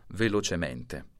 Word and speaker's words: velocemente: velocemente o velocemente. Tutti gli avverbi che finiscono col suffisso mente si pronunciano con la E chiusa.